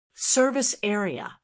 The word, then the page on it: service area: American English